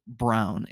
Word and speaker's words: Braun: Brown, it's spelled Braun.